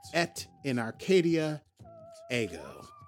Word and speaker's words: Et in Arcadia Ego: But anyway, it's called Et in Arcadia Ego. And I do believe I'm saying that right, because I have started taking Latin lessons.